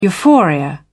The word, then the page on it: euphoria: British English